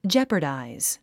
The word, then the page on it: jeopardize: dʒépə r dàiz; ジャパダァイズ